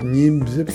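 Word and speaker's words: Niépce: Pas Diep, Niep. – Ça s'écrit N-I. Accent grave, PCE, voilà. – Nieps, voilà.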